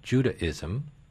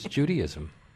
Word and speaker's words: Judaism: One word that I found really interesting that I was very snobby about, I always thought that the word should be pronounced Judaism because mm-hmm. that's the way it's written. – Uh-huh. – And that Judaism was really about some woman named Judy. But the dictionary says it's Judaism.